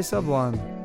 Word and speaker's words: Sublime: It's best to go to Instagram. And I'm going to be playing the song by Sublime. Some people say Sublime. I say Sublime. I mean, it's one word, one syllable. They say that in 40 Ounces of Freedom. If you listen to their song, they call themselves Sublime.